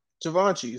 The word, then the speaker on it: Givenchy: Givenchy. Some of y'all still saying it wrong. – Right. Givenchy is how you say it.